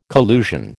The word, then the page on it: collusion: American English